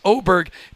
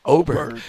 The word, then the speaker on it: Åberg: By the way, I've mispronounced it. It is Åberg. The A with a little O on top. It's Åberg.